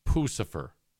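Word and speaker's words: Puscifer: Now, according to this, it's Pucifer. – Pucifer.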